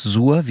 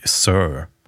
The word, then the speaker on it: Suhr: Äh, nennt sich diese Firma SUHR, nennt sich das Sur? Wie sagt der Ami? – Der also als, als Kartoffel würde ich das auch immer als Sur aussprechen, aber ich glaube, es ist tatsächlich Sir.